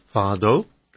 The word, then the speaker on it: fado: It says Fado. Pronunciation is Fado.